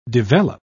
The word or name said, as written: develop